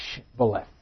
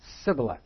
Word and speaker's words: Shibboleth: They couldn't, when you said Shibboleth, which meant flowing stream, here we are at the Jordan, they couldn't say Shibboleth. They would say Sibboleth. That was the closest they could come. That gave them away as being Ephraimites and not native-born Gileadites. They couldn't pronounce it correctly.